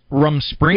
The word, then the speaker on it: Rumspringa: I don't know, Peter, am I pronouncing it right? Rumspringa? – Yes, you are, Doc.